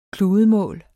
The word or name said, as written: kludemål